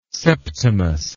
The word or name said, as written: septimus